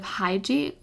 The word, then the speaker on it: Hygge: I really turn to the concept of Hygge or Hygge. I think it's Hygge. It's spelled H-Y-G-G-E.